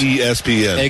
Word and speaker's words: ESPN: But it's not Espen, it's ESPN. – Exactly. Hey. And that's how it's pronounced.